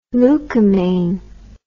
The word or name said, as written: leucomaine